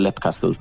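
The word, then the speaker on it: Leap Castle: Fíjate, yo diría Lip Castle en su día, pero como Sean Ryan lo pronuncia Lep Castle, yo me quedé con Lep Castle.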